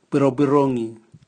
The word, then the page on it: bwirobwirongi: Raga